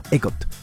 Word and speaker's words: EGOT: De hecho, existe un club muy exclusivo llamado EGOT. Así es. O así lo pronunciamos en español, ¿no?